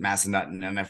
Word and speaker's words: Massanutten: Then we've got It's Massanutten or Massanutten. I've heard both, but I think everyone pronounces it Massanutten.